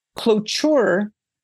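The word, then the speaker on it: Klobuchar: Clochure is pronounced clo with a long O, and C-H-I-R is in chirp. So I think it's clochure.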